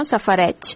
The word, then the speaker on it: safareig: Llavors, eh, sobre aquesta paraula, cal recordar que a l'hora d'escriure-la s'escriu amb una IG al final, eh, que pronunciem x, no? safareig.